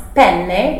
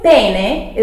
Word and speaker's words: penne: But anyway, here it's very important to pronounce double consonants. Because if you pronounce penne with just one N, you're asking for a penis. Oops! Because pene is P-E-N-E.